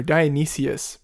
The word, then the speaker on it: Dionysus: Yeah, I'm pretty sure there's an I in there. Dionysius. But... – No. That's, no? – No. D-I-O-N-Y-S-U-S.